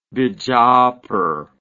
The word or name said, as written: bijapur